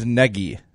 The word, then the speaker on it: Nagy: Yeah, he said it's Nagy. Rhymes with Peggy, but right.